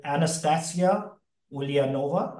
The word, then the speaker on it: Anastasia Ulianova: I'm fortunate to kick off this show today with Anastasia Ulianova.